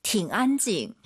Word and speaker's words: 挺安静: 挺安静. – 저 선생님 발음 어때요? 팅 안 올라가죠? 뒤에가 일성이니까 이 삼성이 반삼성이에요.